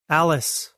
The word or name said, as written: Alice